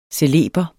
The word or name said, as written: celeber